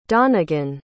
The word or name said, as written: donnigan